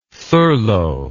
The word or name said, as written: thurlow